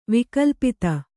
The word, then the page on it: ವೈಕಲ್ಪಿತ: vaikalpita